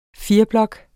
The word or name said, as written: fireblok